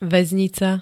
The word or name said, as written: vaznica